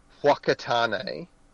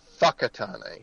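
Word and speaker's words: Whakatane: There's a place actually by Tauranga called, I guess if you were trying to say it phonetically, it would be Wakatane or Wakatane. Because it starts with a WH, it's pronounced Fuckatane.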